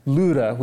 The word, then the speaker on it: Lydda: he came down also to the saints who lived at Lydda," which is how you would pronounce that word.